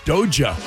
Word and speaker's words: Doja: It's hard J. Doja.